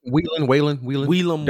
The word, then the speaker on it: Whelan: I did a little research on Paul Whelan. What'd you say his name was? – Whelan. – W-H-E-L-A-N. Him.